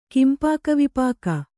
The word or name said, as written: ಕಿಂಪಾಕವಿಪಾಕ